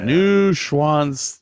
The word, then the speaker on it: Neuschwanstein: Neuschwanzain. I don't know how to pronounce that. Neuschwanzain? – Yeah, something like that. Yeah. Neuschwanstein.